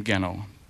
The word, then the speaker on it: Genow: We pronounce the name Genow, spelled G-E-N-O-W.